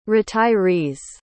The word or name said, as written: retirees